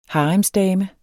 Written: haremsdame

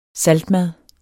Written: saltmad

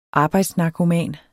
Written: arbejdsnarkoman

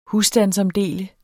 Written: husstandsomdele

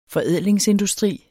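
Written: forædlingsindustri

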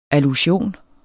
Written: ALLUSION

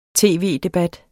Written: tv-debat

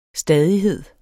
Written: stadighed

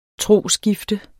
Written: trosskifte

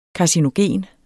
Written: karcinogen

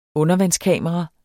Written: undervandskamera

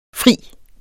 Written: frier

